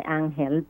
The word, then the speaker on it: ángel: Angel del Brasil, well, in Spanish we say ángel, but angel is singing.